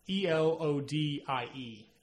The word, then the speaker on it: Elodie: I mean, Elodie Young, E L O D I E. – Elodie. – My bad on my pronunciation there.